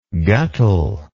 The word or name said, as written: guttle